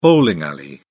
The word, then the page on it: bowling alley: British English